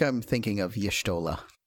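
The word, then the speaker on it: Y'shtola: Yes, it's Yshtola.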